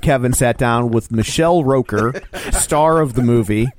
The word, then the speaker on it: Rooker: Michael Rooker, or if you're Joe, Michael Rocker. How did you say it? – I said Roker. – Rooker. – That's Al Roker.